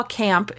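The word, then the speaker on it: Kampe: I think, first of all, Camp is pretty cool, and that's definitely not how I thought you pronounced her name until I looked it up. I was like, Campy. – I was really glad you put the pronunciation in here, because I would have said, like, Campe or Campy, because that E makes you want to say something. Right, yeah, but apparently it's Camp, according to the internet, so, you know, as always, blanket statement of apologies for mispronunciation of anything.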